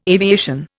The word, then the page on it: aviation: Indian